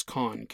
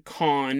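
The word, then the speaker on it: Kohn: I found out that there is a town named Con in Germany. And I found out there is a producer, a music producer from France called Con. spelled the same way, K-O-H-N-N.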